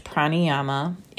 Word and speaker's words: pranayama: it's p-r-a-n-a so there's the prana part and then yama is y-a-m-a and it's pronounced pranayama